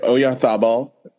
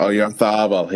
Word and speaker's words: Oyarzabal: Oyarzabal. – Yep. – Oyarzabal.